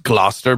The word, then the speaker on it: Gloucester: It's not how it's spelled. It's pronounced Gloucester, but it's spelled Gloucester.